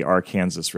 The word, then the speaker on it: Arkansas: We went kayaking on the Arkansas River. Yes, that's how you pronounce it here.